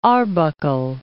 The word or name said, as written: Arbuckle Mountains